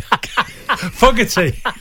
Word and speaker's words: Fogarty: Fogarty.